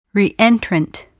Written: reentrant

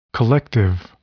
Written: collective

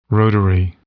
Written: rotary

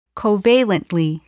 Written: covalently

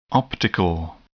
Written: optical

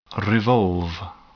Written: revolve

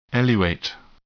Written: eluate

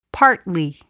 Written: partly

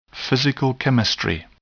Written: physical chemistry

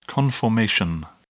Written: conformation

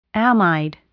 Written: amide

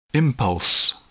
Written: impulse

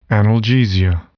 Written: analgesia

